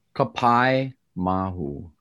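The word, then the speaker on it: Kapaemahu: but Kapai Mahu? Is that close? – Kapai Mahu. – Kapai Mahu. Thank you for correcting me.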